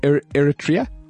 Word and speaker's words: Eritrea: Eritrea? – Yes.